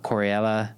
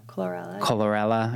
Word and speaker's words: chlorella: Baobab, spirulina, coriella. – Chlorella.